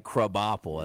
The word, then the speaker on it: Krabappel: Not Krabappel, right? Krabappel, as it's pronounced.